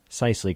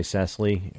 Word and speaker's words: Cicely: Cicely, because I would want to say Cecily.